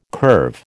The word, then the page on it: curve: American English